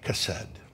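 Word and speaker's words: chesed (חֶסֶד): I had to phonetically write it out because it starts with a KH, but it's chesed. That's how you say it. I listened to it a bunch of times by a Hebrew guy, chesed.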